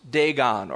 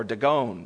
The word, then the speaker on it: Dagon: And they bring it into the temple of one of their gods, Dagon or Dagon. You can pronounce it either way in verse 2.